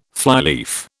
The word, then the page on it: flyleaf: British English